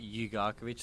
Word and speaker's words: Jugarkovic: It's pronounced, so you say Jugarkovic, so if you say the U and slow it down in between, it's a lot easier.